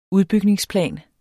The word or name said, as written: udbygningsplan